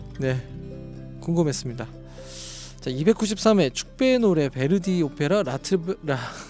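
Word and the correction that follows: La Traviata: 마치 음주한 것 같은 이 발음 라 트라비아타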